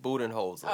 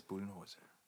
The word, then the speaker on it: Budenholzer: Mike Budenholzer. – Oh, okay. – Yes. – Sorry. – That's Budenholzer.